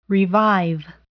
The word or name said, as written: revive